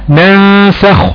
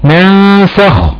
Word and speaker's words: ناسخ: طيب مثال بقى للغنة وراء حرف مرقق، ناسخ ناسخ، النون ساكنة وراء حرف السين، السين حروف الإخفاء، حكم النون إخفاء حقيقي مع غنة مقدارها حركتين، وراء النون حرف سين مرقق، يبقى الغنة هنا مرققة، يبقى ينطقها زي ينطقها ناسخ ناسخ، تمام.